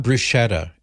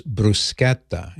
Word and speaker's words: bruschetta: It's not bruschetta. It's bruschetta. And the way it's spelled in Italian, that CH is pronounced like a K.